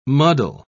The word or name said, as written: muddle